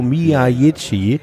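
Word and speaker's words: Miyaichi: Der Mann heißt Rio Miyagi und wird gefälligst auch so ausgesprochen.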